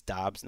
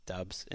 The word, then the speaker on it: Dobbs: Like, if Dobbs is starting, and I did learn over the last week it's Dobbs, not Dubs, and you guys have been saying it right. Apparently, I was saying it wrong.